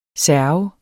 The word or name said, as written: serv